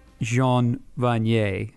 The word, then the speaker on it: Jean Vanier: So I think I'm going to start with Jean Vanier.